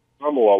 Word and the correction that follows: Asamoah: was right in his face. – Asamoa. – Sorry about that. – That's okay. Asamoah. You put the wrong accent on the wrong syllable.